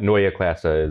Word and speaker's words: Neue Klasse: We've seen so first of all, Neue Klasse, that is my terrible German pronunciation. In English, that just means new class. So for the remainder of the show, for the sake of not getting ourselves tongue-tied, we can just say new class. But Neue Klasse is the proper pronunciation.